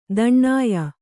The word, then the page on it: ತಾಣ್ಯ: tāṇya